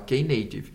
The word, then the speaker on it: Knative: Tem toda uma pronúncia, está escrito no GitHub como fala, K Knative.